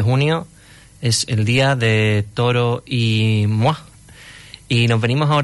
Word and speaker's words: Toro y Moi: Tengo que reconocer que hasta ayer yo mismo lo pronunciaba Toro y Moi, como si fuera Moisés y fueran dos. Pero al parecer su nombre viene de la palabra en español Toro y la francesa Moi, que significa yo.